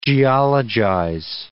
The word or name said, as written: geologize